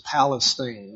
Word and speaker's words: Palestine: And it is pronounced in Texas Palestine, not Palestine.